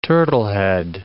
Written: turtlehead

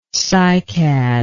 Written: cycadlike

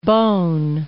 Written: Beaune

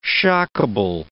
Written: shockable

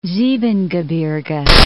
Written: Siebengebirge

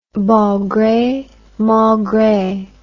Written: bon gre mal gre

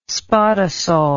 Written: spodosol

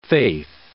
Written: faith

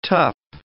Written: tup

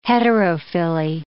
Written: heterophylly

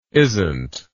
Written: isnt

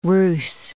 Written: ruse

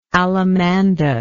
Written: allamanda

